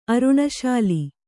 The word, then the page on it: ಅರುಣಶಾಲಿ: Kannada